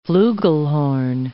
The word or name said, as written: fluegelhorn